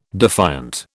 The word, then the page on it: defiant: British English